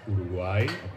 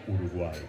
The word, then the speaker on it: Uruguay: The right way of pronouncing it is Uruguay. – Say that again. – Uruguay.